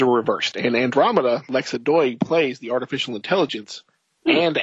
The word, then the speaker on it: Doig: Lexa Doig. – Doig? – Is Doig. That how you pronounce her name? Sure.